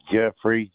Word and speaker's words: Jeffery: Jeffrey, J-E-F-F-E-R-Y. – You said J-E-F-F-A-R-Y? – E-R-Y. – Oh, okay. – E-R-Y.